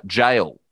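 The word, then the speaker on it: Gaol: But a big one, probably the biggest attraction of Dutton Park is the Boggo Road uh, Jail or Gale, mm. as it used to be spelt. Um, mm. But I believe still pronounced jail.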